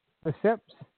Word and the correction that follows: Leipzig: I think it's how you pronounce it. Let me just double check the pronunciation on this. RP Leipzig in Germany.